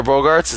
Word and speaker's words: Bogaerts: Xander Bogaerts, is that properly how you say it? – Yeah.